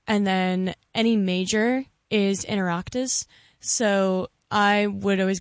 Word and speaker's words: Oireachtas: Oireachtas? – That is correct.